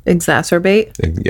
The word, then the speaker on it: exacerbate: Exacerbate? – Yeah. I never know if I'm saying it right. – You said it right.